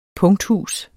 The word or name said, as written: PUNKTHUSENES